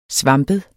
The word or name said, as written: svampet